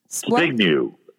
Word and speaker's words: Zbigniew: Zbigniew. So what do you pronounce Zbigniew.